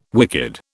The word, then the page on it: wicked: British English